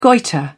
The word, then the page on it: goitre: British English